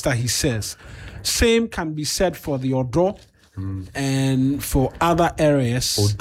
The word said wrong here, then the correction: Udo: They are mispronouncing Udo, Peshi. Yes. Udo.